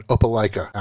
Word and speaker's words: Opelika: You grew up in a trailer park in Opelika, Alabama. What are your favorite memories from home? – Well, you're the first person to ever pronounce Opelika correctly, and that's amazing.